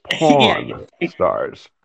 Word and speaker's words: Pawn Stars: Make sure you pronounce that correctly. Let me spell it because with your accent, I wouldn't want people to misunderstand what you just said. It's Pawn Stars. – Pawn Stars.